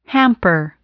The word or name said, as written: hamper